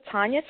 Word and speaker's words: Tanya: Just search for Tanya Thompson, and I will pop up.